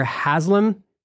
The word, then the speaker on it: Haslam: I've heard our mutual friend, Scott Sauls, told a beautiful story about how you and Governor Haslam, am I saying his name right? – Right.